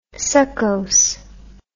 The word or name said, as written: succose